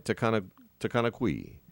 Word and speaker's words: Takanakuy: It's spelled T-A-K-A-N-A-K-U-Y. Am I pronouncing it right? Takanakui.